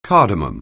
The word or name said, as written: cardamom